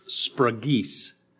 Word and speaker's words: sphragis: The word spragis, S-P-H-R-A-G-I-S, but pronounced spragis.